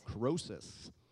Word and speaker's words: Croesus: How do you say that? – Croesus.